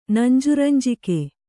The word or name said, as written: ನಂಜುರಂಜಿಕೆ